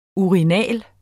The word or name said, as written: URINALE